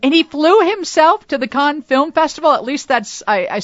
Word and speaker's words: Cannes: I do say that right, Cannes, right? – Yes, yeah. Cannes.